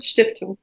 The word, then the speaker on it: Stiftung: I'm going to say this wrong. Rosa Luxemburg Stiftung. Say that again. – Stiftung.